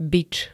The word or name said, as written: bič